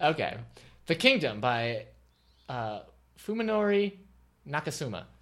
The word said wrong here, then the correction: Fuminori Nakamura: You know what? That's not how you say it. – Your eyes crossed a little bit when you said that. – Fuminori Nakamura. – Okay.